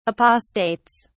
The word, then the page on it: apostates: British English